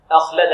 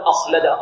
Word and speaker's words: أخلد: هنا أخلد، لا نقول أخلد لا، هذا خطأ، بل نقول أخلد تنطق بالهمزة مفردة، بعد ذلك تأتي بها مع الخاء، لأنه كما يقول علماء التجويد يحدث في التركيب ما لا يحدث في ماذا؟